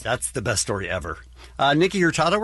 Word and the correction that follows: Nico Hurtado: we're going to talk about today. Did I say that right? Nico Hurtado. Thank you.